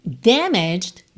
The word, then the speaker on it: damaged: Damaged, danificada. E atenção à pronúncia, damaged. Damaged. Porque como a gente não fala esse a gente não fala damaged, tá? Então, fica meio bem sutil esse final, né?